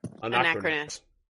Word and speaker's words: Anacrus: Anacrus, is that right? – Anacrus. – Anacrus. Yep, I knew that I'd missed a syllable there.